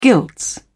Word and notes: gilts: British English

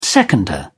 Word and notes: seconder: British English

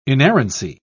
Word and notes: inerrancy: British English

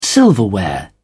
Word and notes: silverware: British English

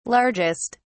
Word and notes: largest: British English

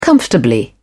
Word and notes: comfortably: British English

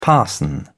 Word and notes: parson: British English

